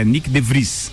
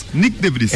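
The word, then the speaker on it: Nyck de Vries: Deixa, desculpa, só pra, pra te corrigir, ele, ele tem aparecido direto, a, a pronúncia dele é Nick De Vries. Nick De Vries. É, De Vries.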